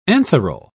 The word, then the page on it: antheral: British English